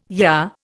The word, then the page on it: yah: Indian